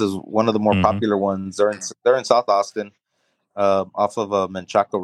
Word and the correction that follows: Menchaca: Road. – Manchac. And so, you know, say it right. – Manchac. – Manchac.